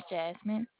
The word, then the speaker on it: Jasmine: I have a number, and my name is Jasmine. I can provide that number whenever you're ready. – Jasmine. You spell that J-A-S-M-I-N.